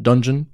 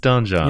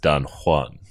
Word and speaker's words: Donjon: No, it's pronounced Donjon. – You think so? – It is, yeah. Because a donjon is not a dungeon. It's like the inner keep. I believe it's pronounced Don Juan.